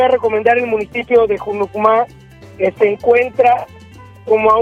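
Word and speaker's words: Hunucmá: Junucumá, como suena como con J. – Sí, pero se escribe con H. Junucumá.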